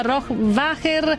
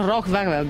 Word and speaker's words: Rohrwacher: Si chiama Rohrwacher, non Rohrwacher, vabbè, lì c'è pronuncia italiana, certamente, insomma, a volte può anche in mille cose che si dicono scappare la piccola imperfezione.